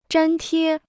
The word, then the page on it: 粘贴: zhān tiē